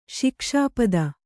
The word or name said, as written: ಶಿಕ್ಷಾಪದ